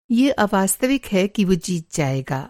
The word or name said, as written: अवास्तविक